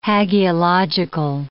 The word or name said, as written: hagiological